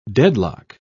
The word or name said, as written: deadlock